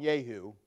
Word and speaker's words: Jehu: I will probably call him Jehu. I was always brought up pronouncing that J with a Y, because it comes from the word Jehovah, Yahweh.